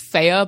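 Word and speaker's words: Fe: Yeah, it's pronounced Faye, but I just keep calling it Faye because it's spelled F-E.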